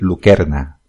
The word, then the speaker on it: lucerna: Eh, lucerna, eh, como supongo que muchos sabréis, lo digo así, pero realmente tiene que decir lucerna, que es como se pronunciaría en latín no existe el sonido de C delante de la E, siempre sería K.